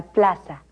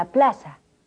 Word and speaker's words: plaza: At the plaza is en la plaza. – The Z is pronounced like an S. Repeat. – En la plaza.